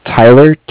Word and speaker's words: Tyler: My name is Tyler, T-Y-L-E-R. – Okay. – And I'm calling with Cardiff. It's spelled C-A-R. D-I-F-F, as in Frank Frank.